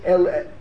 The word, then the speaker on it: Eli: Ellie. – I'm writing E-L-L-Y, so I should pronounce it right, because E-L-I is pronounced Eli.